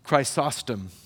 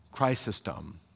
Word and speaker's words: Chrysostom: Third century, one of the church fathers, Chrysostom. I think I'm saying that right. Suddenly it doesn't sound right. Chrysostom. There we go.